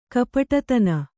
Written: ಕಪಟತನ

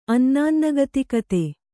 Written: ಅನ್ನಾನ್ನಗತಿಕತೆ

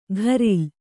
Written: ಘರಿಲ್

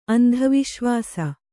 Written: ಅಂಧವಿಶ್ವಾಸ

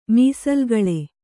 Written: ಮೀಸಲುಗಳೆ

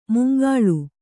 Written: ಮುಂಗಾಳು

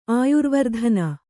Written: ಆಯುರ್ವರ್ಧನ

